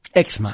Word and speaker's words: Exma: Y fundador de Exma, ¿es Exma? – Exma. Exma, lo dije bien, que es una plataforma de marketing, de negocios, emprendimiento muy grande en Latinoamérica, está en 11 países.